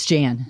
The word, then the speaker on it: Jan: It's Gary, and your name is pronounced Jan. – Yes, it's Jan. – Jan. – In spite of the way it's spelled, just trust me on this.